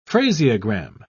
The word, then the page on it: phraseogram: British English